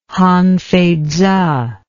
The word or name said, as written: Han Fei Tzu